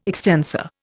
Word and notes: extensor: Indian

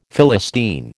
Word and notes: Philistine: American English

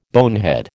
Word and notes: bonehead: American English

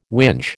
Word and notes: whinge: American English

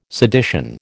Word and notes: sedition: American English

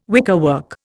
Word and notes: wickerwork: Indian

